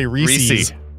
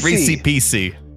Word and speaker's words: Reese's: The Reese's, or as some people say, Reese's. – That's what Kenan Thompson says. Adam. – Well, it rhymes with PC. – Reese PC.